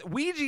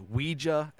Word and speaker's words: Ouija: Ouija is how I grew up saying it. I've heard it pronounced Ouija as well, and I believe that's actually the correct way, Ouija.